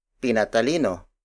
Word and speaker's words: pinatalino: For example, pinatalino, pinatalino, pinatalino. Pinatalino is spelled as P-I-N-A-T-A-L-I-N-O.